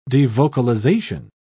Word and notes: devocalization: British English

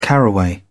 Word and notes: caraway: British English

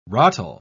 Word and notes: rotl: British English

